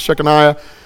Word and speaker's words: Shekinah: Now, the glory of the Lord is sometimes referred to, and people pronounce it different ways. I'm just going to pronounce it as Shekinah.